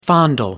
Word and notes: fondle: American English

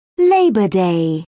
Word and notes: labor day: American English

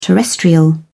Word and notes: terrestrial: British English

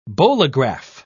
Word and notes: bolograph: British English

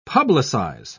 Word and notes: publicise: British English

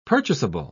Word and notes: purchasable: British English

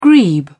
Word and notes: grebe: British English

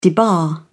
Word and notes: debar: British English

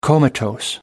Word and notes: comatose: American English

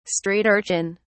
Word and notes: street-urchin: British English

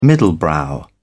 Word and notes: middlebrow: British English

